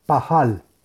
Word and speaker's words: Pajal: Son las letras Pei, Hei, Lamet y se pronuncia Pajal.